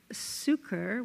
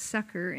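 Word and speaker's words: succour: Sucre, which is the French pronunciation of the word, but someone did explain to me it was actually pronounced sucker in English, but I like French.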